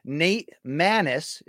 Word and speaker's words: Maness: Finally, the same night, May 6th, Nate Maness is how he pronounces it, Luke. So, like, can you get that right, please?